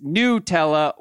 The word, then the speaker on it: Nutella: Once and for all, he says, it is made by an Italian company and the correct pronunciation is Nutella with the U.